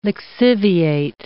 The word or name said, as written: lixiviate